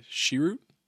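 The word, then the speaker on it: Chirrut: Donnie Yen's character, Shiroot.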